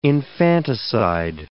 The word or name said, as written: infanticide